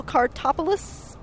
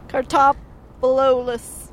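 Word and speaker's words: Kartalopoulos: It's going to have Bill Kartopoulos. I'm saying that wrong. Kartopoulos.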